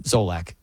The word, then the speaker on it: Solak: So the most entertaining watches, according to Ben Solak, how do you say his name? Zolak.